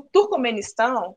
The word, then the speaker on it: Turcomenistão: como é que é um pouco lá o Turcomequistão. É um nome muito difícil, Turcomequistão. Até, já nem sei já tô pronunciando mais certo. esse, o Turcomenistão, como eu falei antes, ele tá na Ásia Central lá, né?